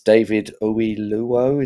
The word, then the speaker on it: David Oyelowo: And also, again, another difficult one to pronounce, it's David Oyelowo. Is that how you say it? That's it. You're better at pronouncing it than me.